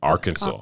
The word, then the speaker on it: Arkansas: It's spelled Arkansas. – Arkansas, yeah. Mm. But it's Arkansas.